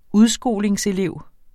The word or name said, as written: udskolingselev